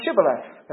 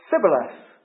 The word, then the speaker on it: Shibboleth: Then said they unto him, say now Shibboleth. And he said, Sibboleth. for he could not find to pronounce it right.